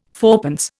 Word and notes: fourpence: Indian

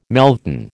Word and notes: melton: American English

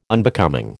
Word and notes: Unbecoming: American English

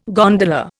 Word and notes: gondola: Indian

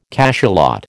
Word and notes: cachalot: American English

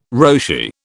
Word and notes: Roshi: British English

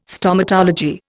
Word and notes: Stomatology: Indian

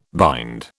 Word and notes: bind: British English

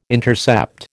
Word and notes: intercept: American English

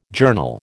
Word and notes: journal: American English

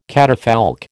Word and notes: catafalque: American English

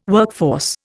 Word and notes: workforce: Indian

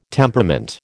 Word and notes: temperament: American English

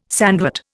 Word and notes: sandwort: Indian

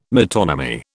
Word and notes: metonymy: British English

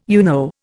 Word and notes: UNO: Indian

